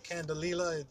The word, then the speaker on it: Candelilla: So you know, you can do it in a lot of ways, but I use a plant-based wax called I'm gonna call it Candelilla. Yeah, Candelilla, that's how I pronounce it.